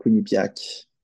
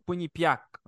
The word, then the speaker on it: Quinnipiac: Ouais, Quinnipiac. Ouais. – C'est Quinnipiac.